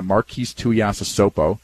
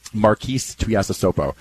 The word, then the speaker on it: Tuiasosopo: He's had a good thing going, and I remember talking to Marquise Tuiasosopo. Remember that name? – He was well pronounced. Well pronounced. – Marquise Tuiasosopo. – Well done.